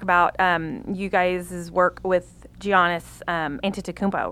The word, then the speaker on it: Giannis Antetokounmpo: It's Giannis Antetokounmpo.